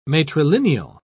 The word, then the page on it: matrilineal: British English